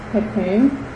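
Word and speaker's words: chokhmah: And it's pronounced Kakum. Kakum, Kakum, yes.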